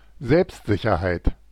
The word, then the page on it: assurance: /a.sy.ʁɑ̃s/; France